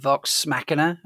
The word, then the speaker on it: Vox Machina: Obviously, The Rig and Vox Machina.